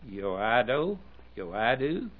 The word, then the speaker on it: Yoido: In one church, and I don't know how to pronounce this, Y-O-I-D-O. Can you pronounce South Korea? Yoido, Yoido.